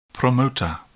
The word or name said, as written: promoter